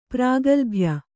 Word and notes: ಪ್ರಾಗಲ್ಭ್ಯ: prāgalbhya

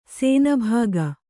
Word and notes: ಶಾನಭಾಗ: śanabhāga

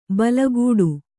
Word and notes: ಬಲಗೂಡು: balagūḍu

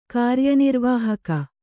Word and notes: ಕಾರ್ಯನಿರ್ವಾಹಕ: kāryanirvāhaka

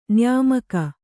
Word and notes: ನಿಯಮಿಗ: niyamiga

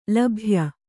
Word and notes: ಲಭ್ಯ: labhya

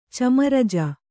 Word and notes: ಚಮರಜ: Kannada

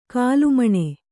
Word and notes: ಕೋಲುಮೀನು: kannada

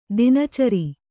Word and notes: ದಿನಚರಿ: dina cari

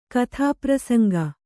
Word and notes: ಕಥಾಪ್ರಸಂಗ: kathāprasaŋga

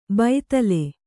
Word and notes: ಬೈತಲೆ: baitale

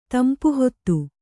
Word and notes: ತಂಪುಹೊತ್ತು: Kannada

